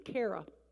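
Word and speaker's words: chara (χαρά): The Greek word for joy, and I probably won't say this right because I'm certainly not a Greek scholar, is C-H-A-R-A, which I believe is chara. It's pronounced chara.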